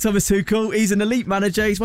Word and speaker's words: Thomas Tuchel: Just quickly, thoughts on Thomas Tuchel. Did I say that right? – You did. Thomas Tuchel.